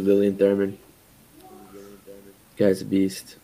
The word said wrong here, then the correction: Thuram: Am I not saying his name right? I'm terrible pronoun- pronouncing names. – Thuram, Lilian Thuram.